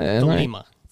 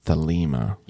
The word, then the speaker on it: Thelema: So developing the Thelema, is it that? – Thelema. – Thelema.